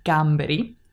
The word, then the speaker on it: gamberi: And again, here we get the hard G sound, gamberi.